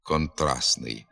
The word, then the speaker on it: контрастний: У прикметниках типу «контрастний», «форпостний» літера «Т» пишеться, але або зовсім не вимовляється «контрастний», або частіше вимовляється «ослаблено», лише з натяком на звук «Т».